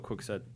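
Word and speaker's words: Kwikset: Quickset? – Yeah, I think we've talked about Quickset before.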